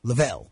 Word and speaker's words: Lavelle: How do you pronounce Rose's last name? – Lavelle.